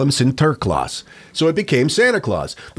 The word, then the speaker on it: Sinterklaas: It's derived from how the Dutch immigrants of New Amsterdam pronounced his name. Not St. Nicholas, but Sinterklaas. – Oh. – Sinterklaas.